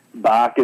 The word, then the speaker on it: Bach: And I didn't say it like Arnold. I said it like it's pronounced. Bach is in the composer.